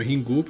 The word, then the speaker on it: Ringu: É, eles têm essa pronúncia diferente do inglês. Então, ring, eles pronunciam ringu.